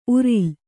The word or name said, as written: ಉರುಲು